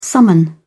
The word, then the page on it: summon: British English